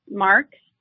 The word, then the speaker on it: Marks: Marks. Last name is spelled M-A-R-K-S, and backwards, it's Graham, which is my company's name.